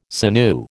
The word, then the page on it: sinew: American English